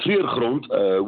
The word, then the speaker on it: Treurgrond: but it's called Thriller Haunt. I can't pronounce it right correctly. – Yeah, that's right. It's called Treurgrond, which means ground is grond, and treur is mourning. M-O-U-R-N-I-N-G.